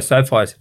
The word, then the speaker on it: SoFi: Is it SoFi? Is that the way you pronounce it? Stadium? – Yeah, we were. So SoFi Stadium, for those that don't know, again, that's based in Inglewood in California.